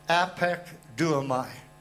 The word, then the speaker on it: apekduomai: The word used there as that verb is the word apekduomai. And I'm going to have to spell it for you so you'll understand what I'm saying. Apekduomai is spelled A-P-E-K-D-U-O-M-A-I.